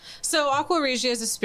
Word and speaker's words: Aqua Regia: Did I get it right? Regia? Yeah. Can you pronounce it better than that? – Aqua Regia.